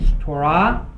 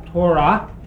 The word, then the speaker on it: Torah: Going to take an excursion through a number of passages that include the word that you've heard pronounced both ways, Torah and Torah. You can put the accent on either syllable, and to be probably most accurate, you'd put it on both, because it really isn't.